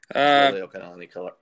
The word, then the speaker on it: Liliuokalani: By the way, Liliuokalani correctly.